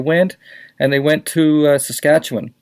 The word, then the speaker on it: Saskatchewan: Can I pronounce that properly? – You said it right. Not Saskatchewan, people. Take it from me. I know somebody who was born there, and you do not say Saskatchewan any more than you say Newfoundland.